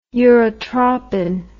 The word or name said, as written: urotropin